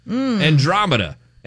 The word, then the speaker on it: Andromeda: Is that it? Andromeda? Did I say it right? – Yeah, Andromeda.